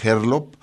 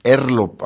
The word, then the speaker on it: Herlop: Y vamos a iniciar con Marina Herlop o Marina Herlop, así es, podemos pronunciar las dos, una más la inglesa, porque en realidad Herlop es una contracción de las primeras letras de sus dos apellidos, Hernández López. Marina es pianista,